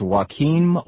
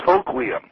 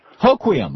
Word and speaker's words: Hoquiam: Is it pronounced Joaquin, Washington? – It's Hoquiam. – Hoquiam. Oh, it's actually pronounced the way it looks.